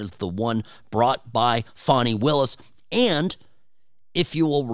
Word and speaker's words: Fani: So, Fani, I used to pronounce it Fani Willis, but I've been corrected. It's Fani Willis is the prosecutor down there in Fulton County, Georgia, who's brought this big case that was going to take down Donald Trump.